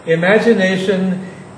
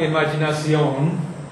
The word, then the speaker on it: imagination: Imagination has three stresses. Imagination. Imagination. There's a little bit more of an accent on the first syllable, which is not imaginación, because it's not Spanish.